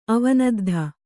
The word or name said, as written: ಅವನದ್ಧ